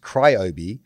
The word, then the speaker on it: Ryobi: Two, the tool brand is pronounced cry is pronounced cryobi, like the sound that wakes you up at night plus Old Ben from Star Wars.